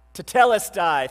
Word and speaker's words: Tetelestai: Tetelestai, thank you. Always put an extra syllable in there. Good job. Tetelestai.